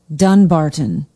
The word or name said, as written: dunbarton